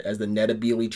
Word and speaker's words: Ndebele: It was kind of a British Empire anachronism that they called them the Ndebele, and it's still called Ndebele land now, but the way you actually pronounce it is more with an N. N-D-E-L-E-L-E. Ndebele. N-D-E-L-E. I can't spell it. Ndebele. B-E-L-E.